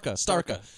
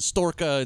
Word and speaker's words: Starka: They go get drunk on this stuff that Mikhail Baryshnikov likes called Sparka. Starka. And Gene Hackman constantly mispronounces it as Storka and Stinka and like all kinds of other shit.